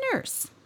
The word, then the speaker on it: Niers: I am a nurse, but I do not know who Peter Nurse is. – Nurse. It's actually spelled N-I-E-R-S, but it's pronounced Nurse.